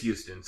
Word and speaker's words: Huston: I don't know if it's Huston or Houston. – It is Houston. Sorry, there is a pronunciation guide. – Yeah, I think it is Houston. – There is a pronunciation guide on her wiki.